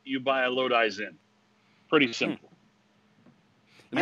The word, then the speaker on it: Lodi: Lodi or Lodi? – It's Lodi. – It's Lodi. Lodi.